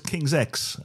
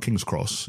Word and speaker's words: King's X: That's King's X, not King's Cross. It's King's X, the band's pronounced.